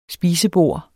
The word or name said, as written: SPISEBORD